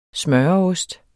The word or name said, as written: smøreost